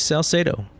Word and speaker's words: Salcedo: You actually did a great job. You pronounced it perfectly correct. It is Salcedo, exactly the way you said it.